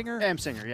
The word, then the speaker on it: Amsinger: So this is MLB Tonight, and it's Greg, is it Amsinger? – Amsinger, yeah.